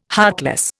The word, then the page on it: heartless: Indian